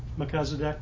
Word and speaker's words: Melchizedek: Melchizedek, yeah.